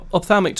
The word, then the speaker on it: ophthalmic: And, of course, now your job is, I might say this wrong, is it ophthalmic director? – I'm the ophthalmic director, okay.